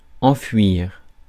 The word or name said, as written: enfouir